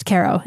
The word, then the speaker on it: Cairo: It's pronounced Cairo in Illinois. The one in Illinois is.